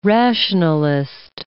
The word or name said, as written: rationalist